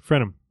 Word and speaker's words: frenum: Okay, that guy is the frenium. Am I saying that right? Frenulum? – Frenum. – Frenum. Okay, the dude.